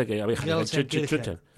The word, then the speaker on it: Gelsenkirchen: Gelsenkirchen.